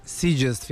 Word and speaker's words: Sitges: Sad ja uvek imam problem da izgovorim. – Mislim da je Sitges. – Sitges Film Festival.